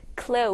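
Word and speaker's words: clothes: 그건 얘들아, 참고로 clothes에 이렇게 지금 es가 붙으면요, 발음이 더 이상 th, the 발음이 아니라 그냥 z 발음이 돼서 close.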